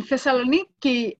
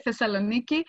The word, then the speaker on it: Thessaloniki: In Thessaloniki, this is how we pronounce it today, Thessaloniki.